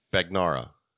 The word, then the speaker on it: Bagnara: Bagnara. – Bagnara. – You were close. – Wrong emphasis on the wrong syllable.